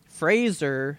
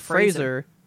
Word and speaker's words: Fraser: Fraser, sorry. He stated that in an interview I watched the other day. He said, Fraser. Rhymes with razor, and I went, oh, you got it.